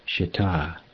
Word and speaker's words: shita: The second syllable should be stressed in the word shita.